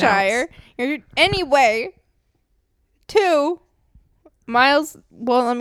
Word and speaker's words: Worcestershire: One, you're just completely forgetting the W at the beginning of that word. – Worcestershire. Worcestershire.